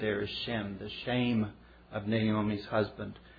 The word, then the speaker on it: Shem: And it's appropriately pronounced shame, but it means the opposite.